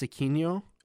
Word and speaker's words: Aquino: Is it Aristides Aquino? – Aquino, yeah.